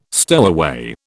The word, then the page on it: stowaway: British English